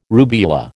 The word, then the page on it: rubeola: American English